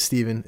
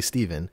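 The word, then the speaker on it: Stephen: My name's Steven. It's spelled with a P-H-N-A, so you'd think it's Stefan, because phonetically that makes sense. But it's actually Steven.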